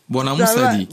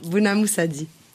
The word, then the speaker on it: Bonamoussadi: Et tu prononces bien, hein? Bonamoussadi. – Bonamoussadi.